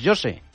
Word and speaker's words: José: Pero José en portugués, José. – José. – ¿Cómo? – José. – Pues como llevo, José. – Llevo 16 años pronunciándolo mal.